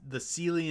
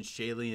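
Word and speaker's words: Seelie: The Sealy and Unsealy, or if you prefer the pronunciation Shaley and Unshaley. As I understand, both pronunciations are correct. It depends on who you're talking to and where you're at.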